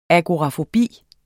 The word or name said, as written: agorafobi